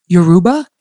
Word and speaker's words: Yoruba: And then reading about them, they sing in English, French, Spanish, and Yoruba.